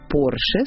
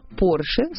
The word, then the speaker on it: Porsche: Фердинанд Артур родился в Австро-Венгрии в семье судетских немцев, поэтому склонять его фамилию на французский манер в корне неверно. Запишем Porsche с ударением на последний слог, а равно Porsche – ошибка. Правильно говорить Porsche с ударением на «о».